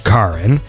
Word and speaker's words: Charon: These indicate only one pronunciation of Charon when referring specifically to Pluto's moon, with an initial K sound.